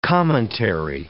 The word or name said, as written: commentary